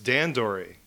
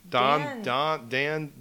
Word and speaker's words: Dondori: Don is like bowl. – That's Dondori. Dondori.